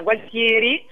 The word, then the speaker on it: Gualtieri: anzi lo pronuncio all'emiliana, Gualtieri, in provincia di Reggio Emilia, e stiamo girando ora.